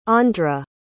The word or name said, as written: aundre